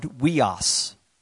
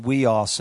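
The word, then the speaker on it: huios: Now, the word for men there, talking about young men, is the word weos. You might want to write that down, weos. And it's not spelled the way it sounds. H-U-I-O-S. H-U-I-O-S, 5207 if you're going to study it out, but it's pronounced weos.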